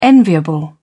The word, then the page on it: enviable: British English